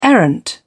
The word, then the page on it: errant: British English